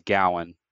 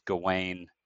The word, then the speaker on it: Gawain: interestingly enough but right i've always either when i studied it in college it was gowan my professor mm-hmm. was pronouncing it that way i looked it up today and it was gawain on google